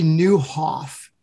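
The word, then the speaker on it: Nieuwhof: There's a book called by a guy named, his name is Kerry Newhoff. And it's not spelled, it's spelled like in a Dutch way. So it's N-I-E-U-W-H, you know, E-I-F.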